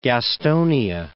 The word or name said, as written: Gastonia